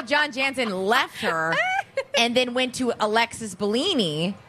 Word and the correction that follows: Bellino: Bellino, right? – Bellino. – Bellino, and they started dating. And she's, how do I say this? Jesus, J-U-G-S.